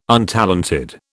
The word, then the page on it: untalented: British English